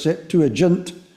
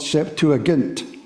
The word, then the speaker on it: Septuagint: Mostly they used the Septuagint for, you'll hear that pronounced as Septuagint, but if you were trained in classical Latin and Greek, then you would use the word Septuagint.